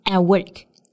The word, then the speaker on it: at work: 我们在读这句话的时候呢，需要注意 at work 这个词组 at 这个单词的辅音，因为后面接的是其他的辅音，所以可以失音爆破不发出来，所以是读成 at work, at work.